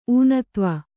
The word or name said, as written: ಊನತ್ವ